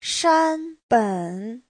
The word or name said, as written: shan1ben3